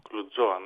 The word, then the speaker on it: Κλουτζόα: Έχει τίτλο Κλουτζόα. Καλά το προφέρω. – Κλουτζόα, ναι, ναι, ναι.